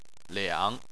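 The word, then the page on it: liang: tone 3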